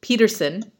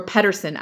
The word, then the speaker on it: Pedersen: Her second ask was, also, I feel like I jinxed Peterson, or Pedersen actually is how you pronounce it, which I just found out, by discussing how small and vulnerable he looks.